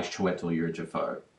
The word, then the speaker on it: Chiwetel Ejiofor: I guess for those who don't know the story, it's about a man played by Chiwetel Ejiofor, who's sort of a Is that how you say his name? – Yeah, that's how you say his name.